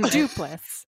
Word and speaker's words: Duplass: Yeah, the Duplass brothers, right? – Mm-hmm. Duplass? – Yeah, and then Duplass.